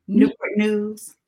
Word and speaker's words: Newport News: And how did you pronounce that? – Newport News. – It's Newport. I always say Newport News. – Yeah, Newport News. – It's Newport. – Hey. – It's Newport News, y'all. I've been saying it wrong all this time.